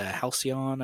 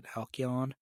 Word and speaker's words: Halcyon: The Halcyon, I think is how you pronounce it. Halcyon.